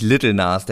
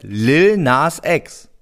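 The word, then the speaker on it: Lil Nas X: Der heißt nicht Little Nas, der heißt Lil Nas Ex.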